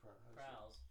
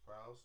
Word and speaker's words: Prowse: Yeah, David Prowse. – Prowse. – All right. Four people played Darth Vader. You guys know that? – No. – Anakin? – No. David Prowse played his body.